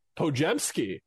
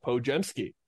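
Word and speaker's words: Podziemski: Pojemski, that's how you say it. I looked it up. I was not even close last time, so a lot of people are going to be saying that wrong. Pojemski, out of Santa Clara, is a shooter with a whole lot of scoring attached to him and an undersized guard.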